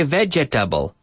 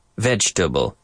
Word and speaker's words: vegetable: Words like vegetable. We don't say vegetable. We say vegetable.